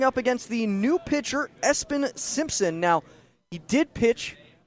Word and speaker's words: ESPN: And if you were wondering, Espen, it is spelled E-S-P-N. That is what his official name is, as that pitch misses outside.